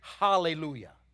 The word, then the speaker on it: Hallelujah: Now, I know in some versions it's spelled with an A, Hallelujah, but actually the A has what's called an aspirate before it, which gives it an H sound. Actually, the word is Hallelujah, okay?